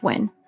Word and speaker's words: Nguyen: Yeah, I feel like that's like commonly how people try to pronounce, but from what I understand, it's pronounced Win. – Mm-hmm. – Like W-I-N.